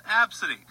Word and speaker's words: ABCDE: Tracy's 5-year-old daughter has a unique name spelled ABCDE, but it's pronounced Absidy.